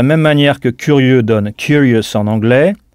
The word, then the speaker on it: curious: Sachez que beaucoup de mots français qui finissent en E, E-U-X, comme par exemple curieux, finissent en O-U-S en anglais, qu'on prononcera us. Donc curieux se dit curious en anglais. C-U-R-I-O-U-S.